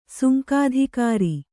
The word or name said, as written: ಸುಂಕಾಧಿಕಾರಿ